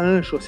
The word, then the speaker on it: Anchor: Para preparar os áudios do nosso blog, vamos usar a plataforma de podcasts Anchor, uma palavra formada pelas letras A-N-C-H-O-R. Seria Anchor se a gente estivesse falando português, mas se fala com a pronúncia, essa pronúncia Anchor.